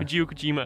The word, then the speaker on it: Hideo Kojima: Hideo Kojima, ja.